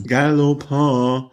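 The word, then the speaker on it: Gallopin: Und Richie Porte hat das dann abgeschossen und hat genug Zeit wieder gut gemacht auf Galopin. Wie spricht man den genau aus? Galopin? – Galopin.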